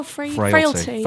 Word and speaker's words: Frailty: Is it Fraicality? Is that how you pronounce it? – Oh, Frailty. – Frailty.